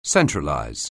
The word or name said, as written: centralize